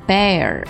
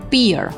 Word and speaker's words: bear: 我们来看第一个单词 bear 啊，我们注意，不要读成 beer 或者 bear，字母 ea 组合发的是一个 e 的音。发这个音的时候开口一直宽就可以了，非常短促干脆有力的哎，然后加一个卷舌音 bear bear bear。